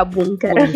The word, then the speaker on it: BNKR: Esatto, perché appunto è scritto BNKR, sì. – Però si pronuncia Bunker.